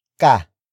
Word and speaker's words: ka: Another rule has to do with the pronoun, ka.